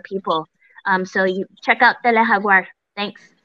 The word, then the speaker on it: Tele Jaguar: And I would also encourage people to check out Tele Jaguar. It's spelled T-E-L-E like Telesur, but Tele-Jaguar. So Tele Jaguar.